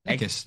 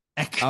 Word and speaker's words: Eckes: Eckes. That's right. – Eckes. I don't know. I don't know how to say it right. – You're really pronouncing the K. Eckes.